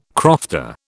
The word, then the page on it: crofter: British English